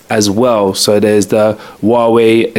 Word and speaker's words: Huawei: Yeah, Huawei came out. That's how you say it, by the way. Huawei, Huawei. I heard loads of people calling it Huawei.